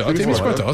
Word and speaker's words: Témiscouata: C'est-tu Témiscouata, c'est ça? – Témiscouata, c'est bon.